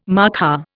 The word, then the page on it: markhor: Indian